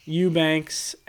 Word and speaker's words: Eubanks: Eubanks.